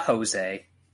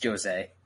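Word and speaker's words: José: Next up, Sean, you're going to have to go first on this one because, man, I'm waiting for the hot takes, but we have Jose, not Jose, it's Jose, Portuguese, Brazilian, hard J, taking on Marlon Vera.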